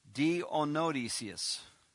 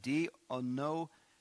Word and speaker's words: Dionysius: Howbeit, I love howbeits, certain men cleaved unto him and believed among them, which was, let me give you this pronunciation, Dionysius. That is a hard name to say. Dionysius.